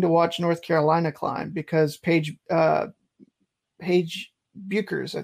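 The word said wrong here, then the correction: Bueckers: I think it's still Beckers. – Beckers, okay. I I, I was uh, make sure I say that right.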